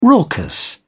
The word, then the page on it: raucous: British English